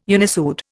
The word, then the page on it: unissued: Indian